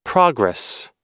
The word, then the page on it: progress: American English